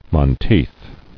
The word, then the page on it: Monteith: mon·teith